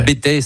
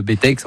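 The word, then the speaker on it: Bettex: Alors le Bétex, certains ne prononcent pas le X, le Bétex, enfin il y a deux écoles,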